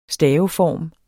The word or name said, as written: staveform